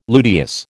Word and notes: luteous: American English

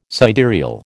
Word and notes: sidereal: American English

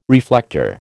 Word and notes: reflector: American English

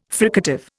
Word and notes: fricative: Indian

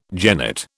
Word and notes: jennet: British English

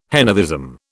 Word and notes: henotheism: British English

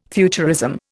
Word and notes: futurism: Indian

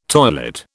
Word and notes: toilet: British English

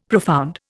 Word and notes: profound: Indian

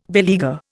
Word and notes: beleaguer: Indian

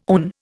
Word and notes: own: Indian

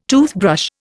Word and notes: toothbrush: Indian

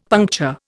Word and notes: puncture: Indian

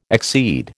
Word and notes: exceed: American English

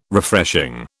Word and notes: Refreshing: British English

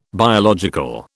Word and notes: biological: British English

